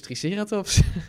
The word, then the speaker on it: Triceratops: Nou, dat is dus Triceratops. – Maar dat is dan de Engelse klemtoon. – Ja, dat is ook flauw van mij, want het mag eigenlijk allebei.